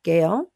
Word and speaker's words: Gale: My name is spelled, I was named after a hurricane, Gale.